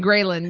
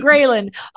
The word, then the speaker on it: ghrelin: all the greptin and the leptin. No, I said it wrong. – The leptin and ghrelin. Yeah. – Leptin and ghrelin.